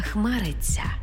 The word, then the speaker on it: хмариться: Також пишемо «ця», але вимовляємо «ця». Наприклад, не «хмариться», а «хмариться».